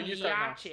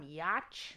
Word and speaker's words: Yauch: Adam Yauch? – Yeah, no idea. I would have said like rhymes with crotch.